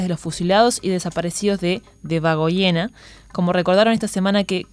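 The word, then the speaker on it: Debagoiena: Por otro lado, en referencia a este mismo tema, familiares de víctimas del franquismo de Debagoyenea, a ver, lo pronuncié mal, ¿no? – De Bagoyena.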